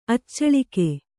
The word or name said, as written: ಅಚ್ಚಾಳಿಕೆ